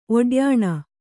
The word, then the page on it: ಒಡ್ಯಾಣ: oḍyāṇa